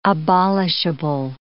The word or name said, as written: abolishable